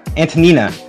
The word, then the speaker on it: Antonina: Caitlin, last names are not our, thank you, pronunciations are not our strong point here on the show, versus Antonia Shevchenko. Antonina Shevchenko, I believe. That's how you pronounce her first name, mm-hmm.